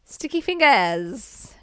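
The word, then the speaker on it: Sticky Fingaz: I gotta pronounce how it's spelled. There's no R in fingers. Fingers. – And it's a Z. – Sticky Fingers.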